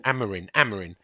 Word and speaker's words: Amorim: Amorim.